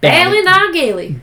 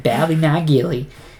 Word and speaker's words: Ballynagilly: You know, you gotta say it like you're a country bumpkin. – Oh, yeah. – Ballynagilly. – Ballynagilly.